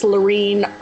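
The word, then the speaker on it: Lorene: And my Facebook account is Laureen R. It's spelled L-O-R-E-N-E-R.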